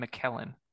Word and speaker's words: McKellen: Did you call him Ian McCullen? – Ian McCullen. – That's not his name. – Am I mispronouncing it? – Yes, it's Ian McKellen.